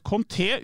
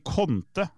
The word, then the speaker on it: Conte: Og så sier hun det at Conte uttales med trykk på E, ikke Conte, som I kontre.